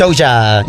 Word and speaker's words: Doja: Is that how you say it? – Doja. Yep. Yeah. Nailed it.